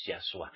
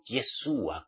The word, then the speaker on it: Yahshua: Y el verdadero Mesías, su nombre verdadero es Yahshua, no Yeshua como algunos lo pronunciáis.